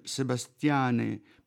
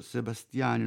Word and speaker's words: Sebastiane: Qui ci sono Sebastiane 1976, lo pronuncio Sebastiane, non Sebastian perché è un film in latino, e Sebastiane è il vocativo di Sebastianus, cioè o Sebastiano. The Angelic Conversation 1985, Caravaggio 1986 ispirato a Pierpaolo Pasolini con Tilda Swinton e The Garden 1990.